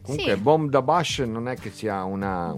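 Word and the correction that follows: Boomdabash: L'ho, eh, l'ho detto bene, sono... Bomb da Bash. – Sì, Boom da Bash. – Boom penso. da Bash. – Io li chiamavo Boom Badash.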